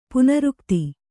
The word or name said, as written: ಪುನರುಕ್ತ